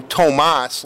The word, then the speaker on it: Tomas: And I want to ask you about the pronunciation of your name, because I used to call you Tomas, like Tomas Holmstrom, and then it sort of evolved over the years.